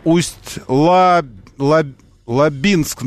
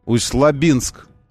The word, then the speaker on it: Усть-Лабинск: Усть-Лабинск, нужно говорить, ударение на «и». У меня там родственники живут, Ресориус пишет. Усть-Лабинск.